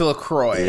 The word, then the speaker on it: LaCroix: I was never sure if it was LaCroix or LaCroix. Like LaCroix? – It's LaCroix. – LaCroix.